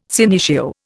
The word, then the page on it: senecio: Indian